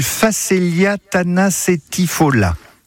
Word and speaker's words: Phacelia tanacetifolia: particulièrement du Phacelia tanacetifolia. – Ah bah c'est pas mal. Ah. Moi j'aurais presque dit lia à la fin, mais bon, si vous direz là, c'est pas mal.